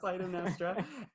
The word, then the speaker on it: Clytemnestra: Am I pronouncing it right? – You're pronouncing it right, yes. – Clytemnestra.